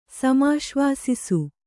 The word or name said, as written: ಸಮಾಶ್ವಾಸಿಸು